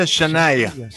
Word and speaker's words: Shania: Ella es Shania Twain y se pronuncia Shania.